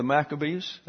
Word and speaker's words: Maccabees: We have the Maccabees. I said that correctly.